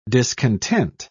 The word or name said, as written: discontent